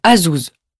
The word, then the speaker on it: Azouz: Mon prénom est B-E-N-J-A-M-I-N. Et mon nom est L-A-F-I-T-T-E. 3. – Coucou, je m'appelle Azouz. Ça s'écrit A-Z-O-U-Z. – 4. Ma femme est anglaise. Son nom est McFaul. M-C-F-A-U-L.